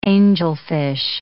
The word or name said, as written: angelfish